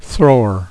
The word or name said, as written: thrower